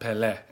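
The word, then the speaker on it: Pelé: Pele himself said, this is the cathedral of world soccer. – First of all, it's pronounced Pele, and you know that.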